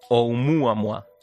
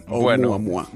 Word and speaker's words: Oumuamua: Muy buenas noches, pues muy bien, muy, muy bien pronunciado, en perfecto hawaiano. – Oumuamua.